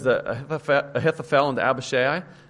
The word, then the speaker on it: Abishai: But I'm actually thinking of Joab's brother, Abishai, as I pronounce it. Abishai, a little bit less known, probably the older brother of Joab.